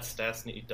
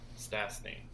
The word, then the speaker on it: Stastny: It's also that they got Stastny Dutton. Well, I said that wrong. Stastny.